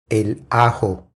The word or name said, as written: El ajo